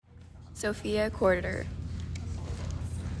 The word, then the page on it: Sophia Corder: so FEE uh COR der